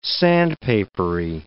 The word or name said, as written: sandpapery